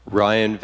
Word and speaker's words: Ryan: Ryan Van Engen, R-Y-A-N, V-A-N, E-N-G-E-N.